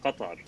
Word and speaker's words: Qatar: Well, so uh, in Arabic, it's pronounced Qatar. – So, Qatar. Yeah. So mm-hmm. there's emphasis on the T part or the T sound. So that's why some people say Qatar instead of Qatar, but both are used.